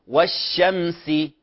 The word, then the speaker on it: والشمس: ولكن لا نريد أن نبالغ، لو ركزنا الكاميرا قليلا، نعم، لا نريد أن نبالغ ونجعل للشين إشماما، كما ذكرنا أن البعض يخطئ في نطق الصاد فيقول أص. لاحظ ضم الشفتين، هذا غير صحيح. نعم الشين تحتاج أش أش، تحتاج إلى حركة الشفتين بنسبة بسيطة قليلة، لكن لا نقول الشمس الشمس وهذا خطأ، فننطقها من غير تكلف، نقول والشمس والشمس، هذا في الحرف المشدد.